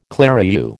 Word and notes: clerihew: American English

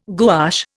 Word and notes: gouache: Indian

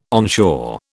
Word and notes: onshore: British English

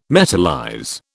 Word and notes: metallize: British English

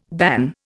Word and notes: ban: Indian

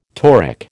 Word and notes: toric: American English

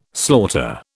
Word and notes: slaughter: British English

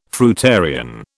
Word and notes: fruitarian: British English

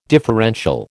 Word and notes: differential: American English

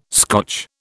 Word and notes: Scotch: British English

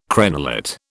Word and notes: crenellate: British English

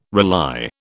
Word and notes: rely: British English

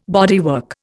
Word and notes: bodywork: Indian

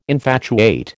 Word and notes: infatuate: American English

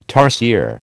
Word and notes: tarsier: American English

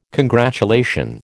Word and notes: congratulation: American English

